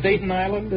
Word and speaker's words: Staten Island: Staten Island, is it? – Staten Island.